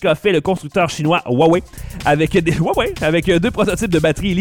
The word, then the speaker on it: Huawei: Allons-y avec UI, ou Huawei,